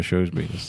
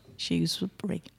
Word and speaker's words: Shrewsbury: I say it like the shoe you put on your foot, Shrewsbury. – Well, I say it like the little rodent is a shrew. Shrewsbury, yeah.